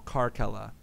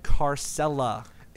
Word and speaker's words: Caracalla: So I found a Roman emperor called Caracalla, and I'm like, all right, I'll take out a syllable, Caracalla. That's cool. And then people kept pronouncing it Carcella, including myself. I apologize. – Yeah, frick you. It's not Carcella.